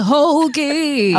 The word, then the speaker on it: Hogi: Francesca Hoagie.